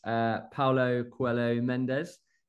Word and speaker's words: Paulo Coelho Mendes: And a warm welcome to this week's guest, Paulo Coelho Mendes. How was my accent, Paulo? Was that good? A good pronunciation? – Pretty good. Yeah, no, can't fault that.